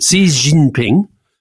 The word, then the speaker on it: Xi Jinping: So I do tend to say Xi Jinping, but I shouldn't say Xi, I should say Xi. François Hollande told me that. He told me I mispronounced it. He said it's Xi. He's Xi. Xi Jinping. Xi Jinping. – Yep. So it goes up, down, up.